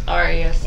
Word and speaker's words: Ariya: It's Ariya.